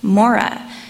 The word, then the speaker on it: Marah: The Syriac translation of Exodus 15 shows that Marah was likely pronounced as Morah, which is pronounced like the first half of the name Mormon.